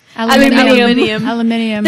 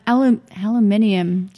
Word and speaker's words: aluminium: But aluminium, you're adding an extra syllable aluminium. – That is not there.